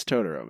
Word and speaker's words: Totoro: Am I saying the right Totoro? Or is it Totoro? – It's Totoro, man.